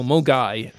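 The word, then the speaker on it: Milngavie: He laid down the Scottish pronunciation challenge last Mungarv. – Week. For Mulgai. Spelt Milngavi.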